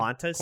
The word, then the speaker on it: Qantas: Qantas? – Qantas? It's Q. – Is it Q-U-A? – It's just Q-A. – N? – Q-A-N-T-A-S.